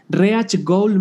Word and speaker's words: Reach Goal: Em, encontrar eh, eh, o para encontrar a, Rich Gold se escribe Reach, Reach Gold México, no es, no es Rich de, rico o de riqueza, como decía Fer, es Reach de alcanzar.